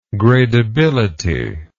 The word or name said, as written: gradability